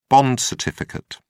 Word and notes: bond certificate: British English

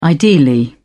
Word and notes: ideally: British English

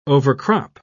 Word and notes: overcrop: British English

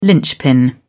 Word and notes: linchpin: British English

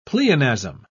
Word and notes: pleonasm: British English

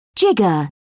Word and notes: jigger: British English

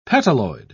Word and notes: petaloid: British English

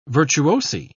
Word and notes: virtuosi: British English

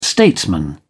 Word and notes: statesman: British English

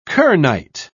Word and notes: kernite: British English

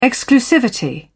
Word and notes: exclusivity: British English

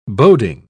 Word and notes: boding: British English

